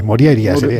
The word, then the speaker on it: Morjaria: Bueno, la J normalmente se pronuncia como I, Moriaria.